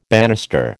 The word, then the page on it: bannister: American English